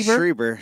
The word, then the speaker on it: Strieber: And how do you pronounce it? It's Whitley Strieber. – Schreiber. – Yeah. Schreiber? – Yeah, I'm pretty sure. – Whitley Strieber. – Whitley Strieber.